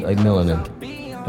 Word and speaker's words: Melanin: Say it right. Melanin. Don't do that.